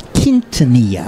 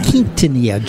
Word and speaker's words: Quintanilla: But you started, it's Q-U-I. – Right. – But you said it should be pronounced like Q-U-E, I think, when we were with him the other day. But it's Q-U-I. It's Quintanilla.